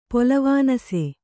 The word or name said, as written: ಪೊಲವಾನಸೆ